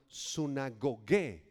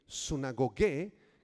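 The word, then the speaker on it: sunagogué (συναγωγή): Los que están tomando apuntes, apunta la palabra sinagoga, en griego es la palabra sunagogué, con acento en la E, sunagogué, que era el lugar donde los judíos se reunían para estudiar la Tanaj.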